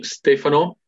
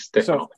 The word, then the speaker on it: Stefano: So my name should be Stefano. It's kind of stressed the first. I know Stefano sounds more Italian for English-speaking people, but it's Stefano.